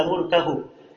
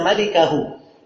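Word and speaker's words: malikahu (ملكه): Al-Bukhari itu tidak bilang illa mulkahu. Dia berkata illa malikahu. Tulisannya sama, mim, laf, lam, kaf, ha. Tapi cara bacanya yang beda. Dia bacanya illa mulkahu. Kalau bacaan yang benarnya illa malikahu.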